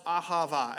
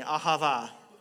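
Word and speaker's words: ahava (אהבה): The word love that we see here is pronounced ahava. Everyone say that with me, ahava.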